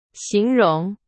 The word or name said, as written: 形容